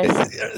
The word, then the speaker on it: Manziaris: Yeah, Manziaris.